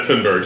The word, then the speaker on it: Thunberg: Greta Thornburg. – Greta Thunberg, yeah.